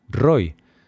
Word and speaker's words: Roi: Y yo, no, no, me llamo Roy. Carlos. Y yo, no, no, se pronuncia Roy.